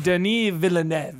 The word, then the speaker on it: Denis Villeneuve: I think it's like Denis Villeneuve. – Denis Villeneuve.